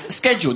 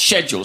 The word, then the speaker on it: schedule: We watch a lot of American TV and we often hear this word pronounced as schedule. That's incorrect. The correct British way is, in fact, schedule.